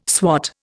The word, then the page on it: swot: Indian